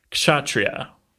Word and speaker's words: kshatriya: The word is kshatriya. – Ooh, well done. – Some people might put a little more emphasis on that K.